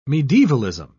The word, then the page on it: medievalism: British English